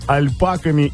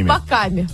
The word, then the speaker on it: альпака: Альпака на последнее А ударение. – Ой, боже.